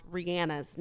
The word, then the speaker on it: Rihanna: You totally missed the part where I was just trying to help you correctly pronounce Rihanna's name. – I just, it's Rihanna.